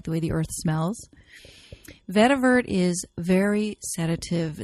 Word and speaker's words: vetiver: I have seen it spelled with a T at the end, but generally it does not have the T, but it's always pronounced vetiver.